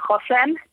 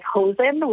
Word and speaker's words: Hosen: In English, it's spelled like Hosen, which people are like, why did he name his party Pants in Yiddish? But he did not. He named his party Chosen, which is resilience in Hebrew.